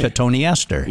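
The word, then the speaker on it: Cotoneaster: Don had to cut a couple of Cotone East Ester shrubs. – Cotone Ester? – Yeah. – Yeah, it's spelled like cotton Easter, but yeah. – Okay. – Cotone Ester.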